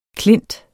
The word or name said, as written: klint